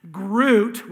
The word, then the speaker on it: Groot: Groot, which rhymes with root, is like this tree-like creature who's big and massive and strong.